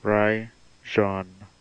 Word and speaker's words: Rajon: Rajon Rondo. Rajon Rondo. – Rajon Rondo. Do. Let's roll that again for the folks who didn't get it. – Rajon Rondo.